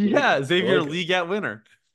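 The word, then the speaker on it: Xavier Legette: Question one, do you guys care that it's actually pronounced Xavier Leggett? – No.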